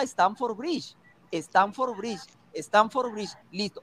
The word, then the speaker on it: Stamford Bridge: Stamford Bridge, ¿correcto? – Claro, Así se pronuncia.